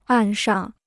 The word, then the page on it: 岸上: àn shàng